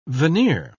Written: veneer

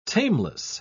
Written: tameless

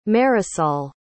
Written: Marisol

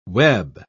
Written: web

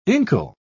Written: inkle

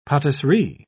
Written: patisserie

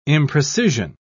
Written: imprecision

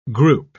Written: group

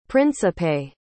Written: principe

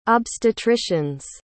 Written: obstetricians

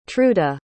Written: truda

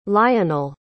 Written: lionell

